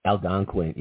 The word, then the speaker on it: Algonquin: Now, the Native Americans, I can't pronounce this word, Algonquin, Algonquin, yeah, the Algonquin.